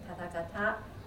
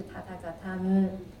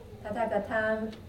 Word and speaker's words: 塔塔嘎坦: 都大半念的都念成塔塔嘎塔，这边呢其实是塔塔嘎坦，塔塔嘎坦啊，萨达杜维宝什伊塔。